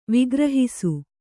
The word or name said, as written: ವಿಗ್ರಹಿಸು